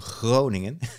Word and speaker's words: Groningen: I went filming in a city called Groningen, and you have to pronounce it with a guttural Klingon G, so Groningen.